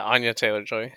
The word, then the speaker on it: Anya Taylor-Joy: Anya Taylor-Joy? – Yeah, Anya Taylor-Joy.